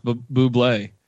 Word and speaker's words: Bublé: He eventually, like, he kept trying to tell people it's pronounced Bublé.